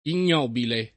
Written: ignobile

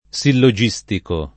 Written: sillogistico

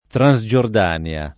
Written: Transgiordania

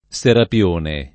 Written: Serapione

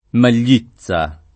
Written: Maglizza

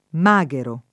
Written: maghero

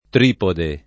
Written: tripode

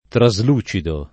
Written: traslucido

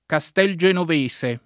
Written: Castelgenovese

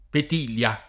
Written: Petilia

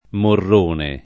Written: Morrone

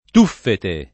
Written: tuffete

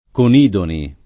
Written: Conidoni

